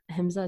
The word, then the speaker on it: ahimsa: emphasize the concept of ahimsa. Is that how you say it? – Yes.